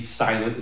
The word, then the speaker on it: SLNT: S-L-N-T, silent is how it's pronounced.